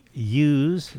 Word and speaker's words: ewe: They raise their dairy ewes. Is that how you pronounce that? – The ewe, yes.